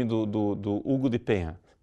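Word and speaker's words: Payens: E se for escrito assim, a pronúncia, né, no alfabeto fonético seria Hugo de Payan. Assim. Payan.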